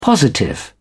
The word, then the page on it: positive: British English